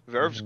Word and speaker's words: VRV: Verve's great. – Yeah, Verve's pretty nice. – Yeah. Oh, it's Verve. I didn't know that.